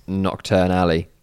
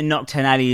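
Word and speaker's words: Knockturn Alley: I do think the Nocturne Alley is spelled, it's not spelled the same way though. – No, it's spelled with the K, as in knock.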